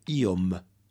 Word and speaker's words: iom: Iomete lo derivamos de la voz simple iom, que significa un poco. Ponga especial cuidado en pronunciar claramente la m final de iom, para no confundirlo con ion, que es io, pero en acusativo.